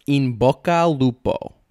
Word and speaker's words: in bocca al lupo: So it's in bocca al lupo.